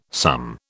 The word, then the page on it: sum: British English